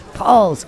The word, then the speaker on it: Pahls: It's spelled P-A-H-L-S. Paul's Country Store, the name you can trust for sheds and buildings for over 20 years, located in Buckley. Stop in, shop their inventory, and get a no-obligation quote. Paul's Country Store, Buckley, and by Lindy Furniture of Sheboygan, serving Northern Michigan for over 134 years.